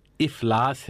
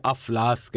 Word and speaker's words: افلاس: درست تلفظ افلاس ہے بمعنی غربت، اسے افلاس کہنا غلط ہے کیونکہ الف کے نیچے زیر ہے، افلاس۔